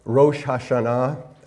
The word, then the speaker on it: Rosh Hashanah: In the fall, we always have our Fall Feast of Israel, and when we celebrated Rosh Hashanah, I say that properly because in Philadelphia it sounds more like Rosh Hashanah.